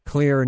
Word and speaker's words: Xlear: Now, what about, you know, there's a lot of different products on the market, not just the Clear nasal spray. And by the way, that's spelled X-L-E-A-R.